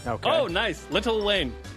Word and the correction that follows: Lil Wayne: No, Lil Wayne. You got to say it right.